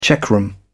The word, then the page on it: checkroom: British English